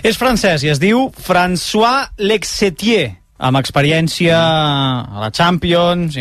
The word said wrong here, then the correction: Letexier: Crec que en realitat és Letexier. – No, no pot ser perquè m'ho he apuntat i és probable que ho hagi apuntat malament en comptes de... – O sigui, de pensar en Texas, no? Letexier.